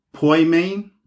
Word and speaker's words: poimen: And it's pronounced poimen or poimen It's spelled P O I M E N.